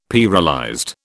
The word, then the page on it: pearlized: British English